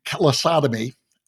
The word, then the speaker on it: callosotomy: You've underwent a corpus callosotomy operation. I mm-hmm. think, did I pronounce that right? Callosotomy? – That's right.